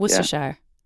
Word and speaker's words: Worcestershire: Worcestershire.